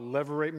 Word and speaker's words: levirate: I believe it's pronounced, levirate marriage. Is that right, Monty? Is that close enough? Thank you. I meant to ask you this morning. It's what it's called, levirate marriage.